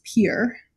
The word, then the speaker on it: Pierre: Second of all, the capital of South Dakota, it's spelled P-I-E-R-R-E. It is pronounced peer, like P-I-E-R, is one syllable. Do not pronounce it two syllables. You are incorrect.